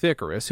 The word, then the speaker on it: Icarus: Another one of the freshly risen brands to the Kansas City urban fashion sphere is Deontay Howard's modern Christian streetwear brand Icarus Clothing, pronounced like the Greek myth Icarus, who flew too close to the sun.